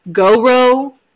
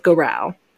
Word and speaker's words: Gowrow: Now, this cryptid has many different pronunciations of the name. I have dedicated myself to the pronunciation of Garau. It is spelled G-O-W-R-O-W. So I have heard Go-Row, Go-Row, Garau. I'm sticking with Garau. It feels the best.